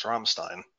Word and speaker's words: Rammstein: Spelled Ramstein, but pronounced Rammstein. Flat A or O sound.